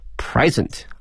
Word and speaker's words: present: 我们想到礼物啊有两个词，一个是 gift G-I-F-T 可以哈，还有一个呢是 present P-R-E-S-E-N-T 这个词啊，如果表示礼物的话呢，它的发音是 present 啊，注意一下它的发音呢重音在前面啊 present。